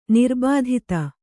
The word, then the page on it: ನಿರ್ಬಾಧಿತ: nirbādhita